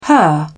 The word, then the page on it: her: British English